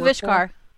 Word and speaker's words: Vishkar: I apologize, but the Vardkar, Vardkar, um, Symmetra's supporters and backers, the company that they the Vishkar.